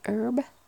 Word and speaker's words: herb: Herb, which I'm pretty sure in the US you don't pronounce the H. Is that right? You say herb. Very herby bread.